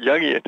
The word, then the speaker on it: Jungian: You just said it right, Jungian.